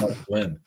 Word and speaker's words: muslin: Is it pronounced muslin cloth or muslim cloth? – Yeah, that's Josh Taylor. I think it's muslin.